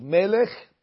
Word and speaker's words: melech: And therefore, as important as tune is, you really should try to keep the pronunciation of the words uh, correct. So melech, malach, yimloch.